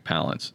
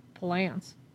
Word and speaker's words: Palance: And I didn't even know that's how you pronounced it. I thought it was Palance. – Jack Palance.